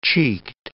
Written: cheeked

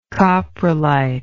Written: coprolitic

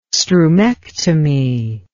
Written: strumectomy

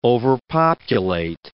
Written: overpopulate

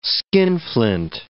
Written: skinflint

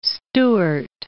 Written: Elizabeth Stuart

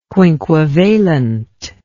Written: quinquevalent

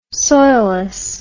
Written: soilless

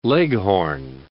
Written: leghorn

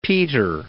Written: peter

